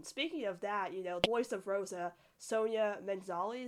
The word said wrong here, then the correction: Manzano: Manzano, yeah.